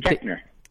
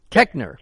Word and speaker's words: Koechner: Koechner. Koechner.